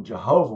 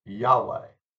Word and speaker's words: Yahweh: First off, the pronunciation Jehovah, while important to witnesses, is really not the proper pronunciation. The Hebrew tetragrammaton, YHWH, was probably pronounced Yahweh.